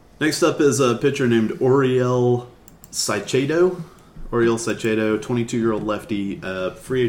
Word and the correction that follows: Caicedo: I can tell you his proper pronunciation is Caicedo. – Caicedo. – Yes. – Awesome. – That was that's my in-law's family name, Caicedo.